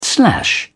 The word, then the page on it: slash: British English